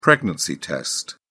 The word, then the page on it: pregnancy test: British English